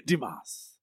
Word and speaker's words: Dumas: Dumas. Dumas is the correct way to say it, unless you go to the Shawshank Redemption School.